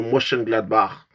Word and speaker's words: Mönchengladbach: C'est tout simplement Mönchengladbach, comme ça que ça se prononce en allemand, contre le Borussia Dortmund, alors que l'un des équipes surprises de ce championnat, c'est le VfB Stuttgart qui va affronter l'Eintracht Francfort.